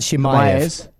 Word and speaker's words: Chimaev: Kamzat Chimaev.